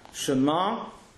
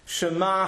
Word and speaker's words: Shema: Right, well, you know as well as I do, Shema has got an ayin at the end of it. If you hear of Avraham Gurovitz in Gate said, regularly the ayin is pronounced some kind of NG sound.